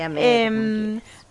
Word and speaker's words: Ahmed: Sí, y ahí Sara, nunca sé cómo pronunciarlo, Ahmed, porque bueno, es Ahmed con una H ahí. – Es una H aspirada, ponele, Ahmed.